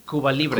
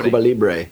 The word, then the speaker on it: Cuba Libre: Cuba Libre. – Cuba Libre.